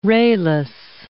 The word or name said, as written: rayless